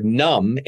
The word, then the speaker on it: numb: So in English, the word numb, N-U-M-B, why is there a B on the end of it? – French is even worse.